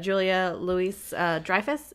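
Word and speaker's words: Julia Louis-Dreyfus: Julia Louis Dreyfus is the mom. I think that's how you pronounce it. – Yep.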